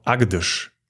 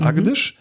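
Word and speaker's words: Agdz: Und ja, nach ungefähr 80 Kilometern kamen wir in den Ort Agdesh. Ich glaube, so spricht man das aus.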